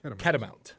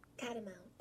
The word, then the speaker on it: Catamount: Catamount. – Oh, is that the pronunciation? – Catamount. – Okay. – What is it? – Catamount. – Okay, just wanted to make sure.